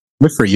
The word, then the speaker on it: midwifery: Did I say that right? – Midwifery, you nailed it.